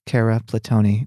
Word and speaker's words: Cara Platoni: And today, dear listeners, my goodness, dear listeners, we have Cara Platoni, which rhymes with... – Scarabad Pony. – Correct. – Unfortunately. – Or fortunately, because that's an easy mnemonic.